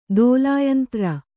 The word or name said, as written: ತೈಲಯಂತ್ರ